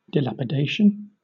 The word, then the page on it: dilapidation: British English